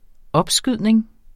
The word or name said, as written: opskydning